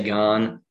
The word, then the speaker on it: Gane: I don't know how people pronounce it. He's French like me, so I'm going to say Gane.